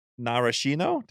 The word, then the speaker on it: Narashino: but some of the indicia for success at Narashino. Did I say it right? – Yes.